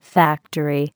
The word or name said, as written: factory